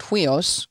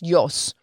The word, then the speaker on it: huios: Now, you might hear some people pronouncing it huios, because that's what it looks like. Uh-huh. H, of course, it's written in Greek. – Yeah. – So it uses different letters. But when you transliterate it into our letters, it's spelled H-U-I-O-S, but it's pronounced yos.